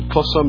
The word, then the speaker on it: Xhosa: He's a Xhosa man. How do you pronounce the Xhosa? this again? It's an X, it's a click.